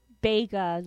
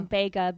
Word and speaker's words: Bega: Now, isn't it Lou Vega or is it Lou Bega? – I think it is Bega. – Like, is it Bega? – Bega, B-E-G-A. Is that how it's spelled?